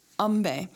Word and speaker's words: Ambe: And that's Ambe is spelled A-M-B-E. I believe it is pronounced Ambe.